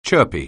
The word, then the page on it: chirpy: anglais